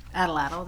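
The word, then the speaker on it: atlatl: Well, let's see, there's a thing called Adelatl. How's that pronounced? – Yes, Adelatl, that's correct.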